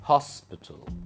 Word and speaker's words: hospital: So you have to stress on hos. Hospital.